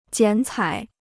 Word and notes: 剪彩: jiǎn cǎi

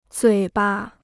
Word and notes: 嘴巴: zuǐ ba

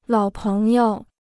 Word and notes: 老朋友: lǎo péng you

